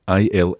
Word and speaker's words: ils: So we've got il in the plural, I L S, il. – Il. – But the S is silent unless it's followed by a vowel.